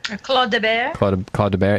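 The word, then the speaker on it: Clos de Bèze: How would you say that? Claude de Bear? – Claude de Bear.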